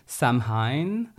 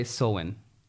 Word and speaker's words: Samhain: Like this, I would say Samhain, but they say Samhain.